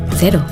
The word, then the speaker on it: Cero: In New Zealand, zero alcohol Corona cero is pronounced cero.